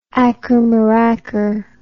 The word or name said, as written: ackermaracker